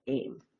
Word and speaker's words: AEM: But even though it's spelled AEM, we still call it AIM.